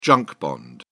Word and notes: junk bond: British English

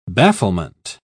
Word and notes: bafflement: British English

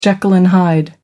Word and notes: jekyll and hyde: American English

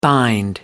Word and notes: bind: American English

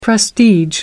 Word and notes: prestige: American English

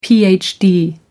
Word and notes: phd: American English

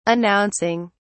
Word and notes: announcing: British English

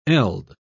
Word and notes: eld: British English

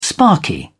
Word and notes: sparky: British English

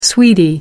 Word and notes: sweety: American English

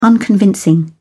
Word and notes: unconvincing: British English

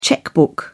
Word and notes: checkbook: British English

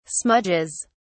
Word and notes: smudges: British English

